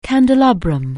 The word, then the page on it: candelabrum: anglais